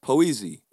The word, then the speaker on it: poésie: So, for instance, in French, you would find that accent above the E in poetry, and it's pronounced poésie in French.